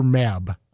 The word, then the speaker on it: Mab: Even when you don't have, you know, Osla said, Mab said, is it Mab or Mab? I wasn't sure when I was reading. How do you pronounce it in your head when you were writing this? – I pronounced it Mab, like Queen Mab from, you know, Shakespeare.